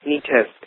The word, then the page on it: neatest: American English